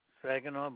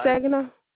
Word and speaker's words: Saginaw: Saginaw, but it's Saginaw.